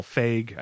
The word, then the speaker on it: Feig: Director Paul Feig, I think I'm pronouncing that right.